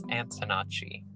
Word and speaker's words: Antonacci: I actually don't know how to say your last name. – Yeah, I don't think a lot of people know, but it is Antonacci.